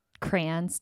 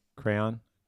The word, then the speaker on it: crayon: So is that just crown? Like a single syllable for you? – Crayon. – Crayon. – Okay. – Duh. – I always thought it was two syllable. Crayon.